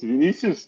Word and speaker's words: Vinicius: It's Vinicius.